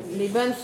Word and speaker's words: Lebens: Lebens, vous prononcez bien le S à la fin.